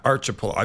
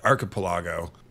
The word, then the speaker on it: archipelago: Whether it was an archipelago, not archipelago, I've been corrected, archipelago, or an isthmus, which is hard to say even if you know how to say it.